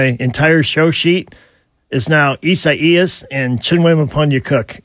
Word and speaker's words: Isaias: It's Isaias in Spanish. – Isaias. – Yeah, but the National Hurricane Center decided to go with the Spanish pronunciation.